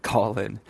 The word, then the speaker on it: Callin: It's spelled Callin. If you forget what it's called, just think about what you're going to do on the app. You're going to call into the show.